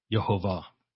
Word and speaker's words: Yehovah: Now, let me just spend a moment to talk about the pronunciation, Yehovah. So this is, if we were to write this out, we will see, going from right to left, we'll see the Hebrew letter Yod.